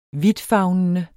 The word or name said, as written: vidtfavnende